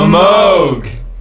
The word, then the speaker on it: Moog: The Moog! – Oh. – You have to pronounce it correctly. It was named after its inventor, Dr. Robert Moog.